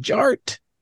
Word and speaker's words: DJarTT: And my name is Jared, aka DJ Art, with two T's for a double dose of that tink tink. The D is silent, so it's just Jart.